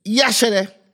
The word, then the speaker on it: Yashere: So the I is almost silent. Okay. So it's spelled I-Y-A-S-H-E, but it's Yashere. So it's Yashere.